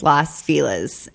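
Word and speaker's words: Los Feliz: We were in, I know how to pronounce it now. It's Las Feliz. I've been corrected.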